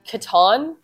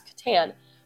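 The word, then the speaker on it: qatan: It's Catan, not like the board game, that's Catan, but it's Q-A-T-A-N.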